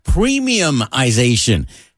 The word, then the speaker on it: premiumization: This new word is premiumization. Premium and then ization.